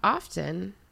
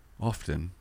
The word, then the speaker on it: often: What about often? – Often. – Yeah. Did you know that the T in often technically is silent? – Often.